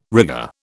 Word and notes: rigour: British English

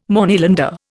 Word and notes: Moneylender: Indian

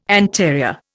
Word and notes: anterior: Indian

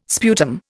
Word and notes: sputum: Indian

